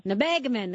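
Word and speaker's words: Nebagamon: I'm going to say it wrong. Nebagamin. Is that right? – Nebagamin. – That you make it sound so much better.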